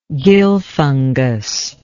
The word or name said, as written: gill fungus